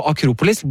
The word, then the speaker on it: Akropolis: I Hellas så slutter gjerne gutte- og hannkjønnsord på S, men er stum. Så Akropolis blir Akropoli på trikken.